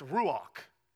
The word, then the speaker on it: ruach: It's ruach. Ruach. You kind of have to say it like you got popcorn in the back of your throat if you want to say it the right way.